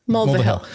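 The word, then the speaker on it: Mulvihill: Mulva Hill.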